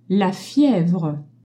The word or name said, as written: fievre